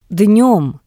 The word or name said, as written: Dnem